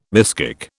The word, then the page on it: miskick: British English